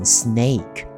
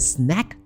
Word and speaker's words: snack: 它叫做 snack time 啊，注意念这个 snack 字的时候，千万不要把它念成 snake，变变成一条蛇。 Snake time，Oh my God，吓死人了。 OK，所以是 snack time。